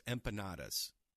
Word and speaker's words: empanadas: Okay, so they beat Bobby Flay, and she made one of my favorite things, empanadas. Am I saying that correctly? – Empanadas.